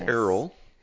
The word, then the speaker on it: Errol: His name is Errol. – So I was actually Which rhymes with Merrill.